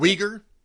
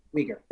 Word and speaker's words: Uyghur: They're putting it in front of us with the Uyghur, how do you pronounce the Muslim minority? How do you pronounce Uyghur? – Uyghur, thank you.